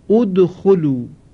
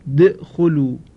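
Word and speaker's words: ادخلوا: چون تلفظ این کلمه با دال ساکن مشکل هست، مثلا بخوانیم د خلو، د خلو، خب این مشکل هست. برای سهولت در تلفظ یک همزه‌ای بر سر این کلمه د خلو در آوردن، شد اد خلو، فلذا تلفظ این کلمه آسان شد.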